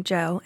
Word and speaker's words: Djo: In fact, Keery has been making music long before taking on his Joe persona, spelled D-J-O, but pronounced like his first name, Joe, in 2019.